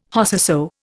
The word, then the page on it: Whosesoe: Indian